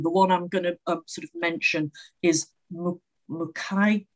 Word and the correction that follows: Mukayu: I knew I'd pronounce it wrong. Mukai, it's not even written on the book. Mukayu, it's Japanese.